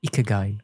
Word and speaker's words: Ikigai: It's called Ikigai.